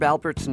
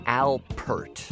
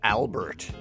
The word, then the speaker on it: Alpert: And Herb Alpert's name is spelled with a P, so it should be Alpert, right? Though, I gotta say that when I was a kid, everyone said Herb Albert, as if it were a B. So we called his office just to be sure, and they confirmed it should properly be pronounced Alpert, which didn't stop us from mushing our consonants together.